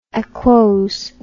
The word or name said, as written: aquose